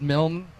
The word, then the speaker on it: Milne: It's pronounced Milne, like A-M-N.